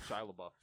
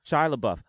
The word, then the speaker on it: Shia LaBeouf: Is it Shia LaBeouf or Shia LaBeouf? – Shia LaBeouf. Shia LaBeouf.